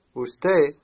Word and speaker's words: usted: Usted means you. Notice that the final D is not pronounced.